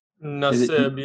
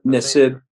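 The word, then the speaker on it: Nassib: Did I pronounce that right? I know you. – Nesib, yeah. – Nesib.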